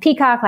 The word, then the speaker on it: Peacock: And it's spelled D-E-S-H-A and then Peacock like the bird.